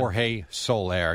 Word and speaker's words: Jorge Soler: Yep, George Soler, Jerry. This will happen. – Jorge Soler.